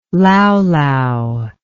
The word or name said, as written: laulau